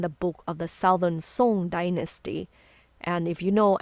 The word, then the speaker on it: Song: In northern Song, Song, it's spelled S O N G like Song, but it's Song Dynasty.